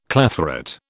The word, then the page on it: clathrate: British English